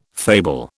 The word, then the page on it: fable: British English